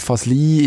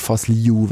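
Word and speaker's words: Fazliu: Und äh, er hat uns gesagt, Fasli ist die schweizerdeutsche Aussprache eigentlich schon die akzeptierte, ist auch okay, wenn man es so sagt, weil es so im Schriftbild nachher sich orientiert, Fasliu wäre ganz korrekt.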